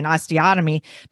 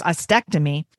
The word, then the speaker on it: ostectomy: I was saying osteotomy, but it's ostectomy.